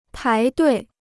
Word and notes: 排队: pái duì

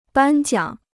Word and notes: 颁奖: bān jiǎng

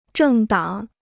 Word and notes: 政党: zhèng dǎng

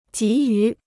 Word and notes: 急于: jí yú

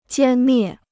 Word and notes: 歼灭: jiān miè

